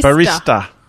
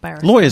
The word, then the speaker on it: barista: Thought a barrister was a kind of lawyer. – Barista. – Oh. – Not barrister.